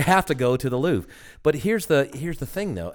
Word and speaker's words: Louvre: Louvre. It's just pronounced Louvre. Louvre.